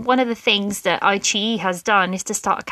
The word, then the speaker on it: iQIYI: And then there's iQIYI, and that's spelled I-Q-I-Y-I, but it's read iQIYI, which has actually also started to carry some Korean dramas on there.